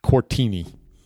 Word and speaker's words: Koritni: It's spelled K-O-R-I-T-N-I, Quartini.